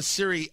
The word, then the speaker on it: Serie: Now, some people pronounce it Oliva, O-L-I-V-A. It's not Olivia, it's Oliva or Oliva. There is something called the Serie O, and I'm not saying Series. It's that there's no S at the end. Serie.